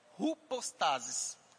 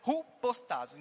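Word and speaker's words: Hupostasis (ὑπόστασις): Contudo, em todas as versões, essa palavra certeza é a palavra grega hipostasis. Hupostasis, a pronúncia dela. A palavra grega hupostasis.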